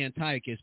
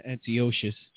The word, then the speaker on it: Antiochus: But didn't I say, I think it's really Antiochus, but we say Antiochus.